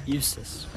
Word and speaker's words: Eustace: Eustace.